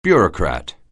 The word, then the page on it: bureaucrat: anglais